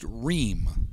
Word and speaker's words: Rheaume: Spelled R-H-E-A-U-M-E, like Rehm in French, but pronounced Rehm.